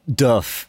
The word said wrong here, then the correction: doof: Doof. Duff. Oh, okay. – It's like a ooh, like oof.